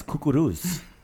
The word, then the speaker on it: kukuruz: The word for corn is kukuruz.